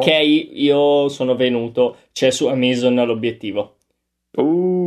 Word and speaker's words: Amazon: No, perché anch'io pensavo fosse tipo da Amazing, ma non è da lì, è da Amazon che è come il Rio delle Amazzoni. Quindi se non sbaglio l'accento è eh su sì, eh, Amazon.